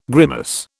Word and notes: grimace: British English

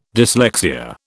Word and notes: dyslexia: British English

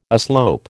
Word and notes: aslope: American English

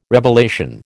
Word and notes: revelation: American English

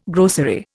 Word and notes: grocery: Indian

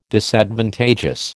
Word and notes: Disadvantageous: American English

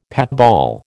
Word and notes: patball: American English

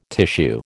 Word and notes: tissue: American English

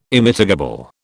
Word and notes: Immitigable: British English

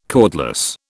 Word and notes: cordless: British English

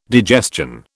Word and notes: digestion: British English

